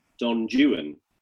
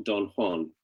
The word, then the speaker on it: Don Juan: Well, I mean, what it puts me in mind of is that the Byron poem should be pronounced Don Juan, not Don Juan, because one of the lines requires that. In order for it to make sense in terms of the kind of scansion.